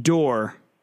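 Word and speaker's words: Doerr: Remind me how to pronounce her last name. – Dorr. Abby Dorr, yes. – D-O-E-R. Okay, but there's an E in there, right? – Yeah, it's D-O-E-R-R. Yeah.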